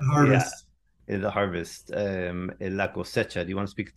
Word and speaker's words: cosecha: So the next one was uh, I'm going to say this wrong. La cosecha is it La Coesha? – Uh, la cosecha. – That's la cosecha.